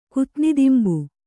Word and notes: ಕುತ್ನಿದಿಂಬು: Kannada

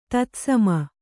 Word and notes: ತತ್ಸಮ: Kannada; tatsama

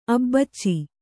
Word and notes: ಅಬಚಿ: Kannada

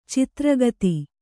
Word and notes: ಚದುರೋಕ್ತಿ: cadurōkti

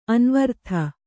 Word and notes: ಅನ್ವರ್ಥ: Kannada

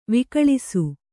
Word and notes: ವಿಕಳಿಸು: kannada

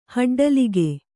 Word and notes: ಹಡ್ಡಲಿಗೆ: haḍḍalige

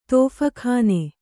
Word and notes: ತೋಫಖಾನೆ: Kannada

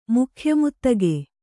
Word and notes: ಮುಖ್ಯಮುತ್ತಗೆ: kannada

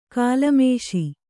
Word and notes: ಗಲಮೀಸೆ: galamīse

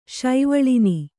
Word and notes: ಶೈವಳಿನಿ: kannada

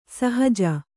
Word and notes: ಸಹಜ: sahaja

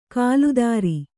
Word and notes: ಕಾಲ್ದಾರಿ: kāldāri

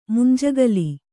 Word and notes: ಮುಂಜೋಗಿಲ್: Kannada

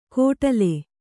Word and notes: ಕೊಡಲೆ: kannada

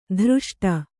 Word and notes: ಧೃಷ್ಟೆ: dhřṣṭe

